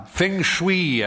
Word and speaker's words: Feng Shui: Feng Shui, as Linda told us it should be pronounced,